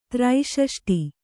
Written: ತ್ರೈಷಷ್ಟಿ

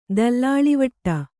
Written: ದಲ್ಲಾಳಿವಟ್ಟ

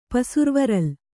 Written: ಪಸುರ್ವರಲ್

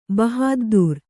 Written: ಬಹಾದ್ದೂರ್

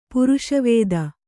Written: ಪೌರುಷವಾದಿ

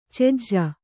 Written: ಚೆಜ್ಜ

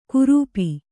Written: ಕುರುಪಿ

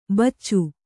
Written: ಬಚ್ಚು

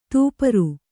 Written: ತೂಪರು

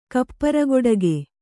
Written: ಕಪ್ಪರಗೊಡಗೆ

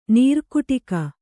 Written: ನೀರುಕುಟಿಗ

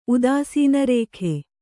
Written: ಉದಾಸೀನರೇಖೆ

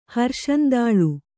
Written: ಹರ್ಷಂದಾಳು

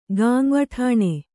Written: ಗಾಂವಠಾಣೆ

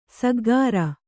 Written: ಸಗ್ಗಾರ

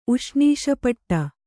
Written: ಉಷ್ಣೀಷಪಟ್ಟ

